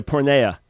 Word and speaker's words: porneia: My question today deals with the Greek word called, and I hope I'm pronouncing this right, porneia? – Yeah, porneia.